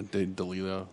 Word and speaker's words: D'Elia: You've reviewed Chris, is it D'Elia? Is that how you say his name? – Who cares, yeah. D'Elia.